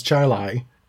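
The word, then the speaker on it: Chili: Because you have towns named Chile that you pronounce Charlie, and towns that are spelt Charlotte, and you pronounce it Shalot.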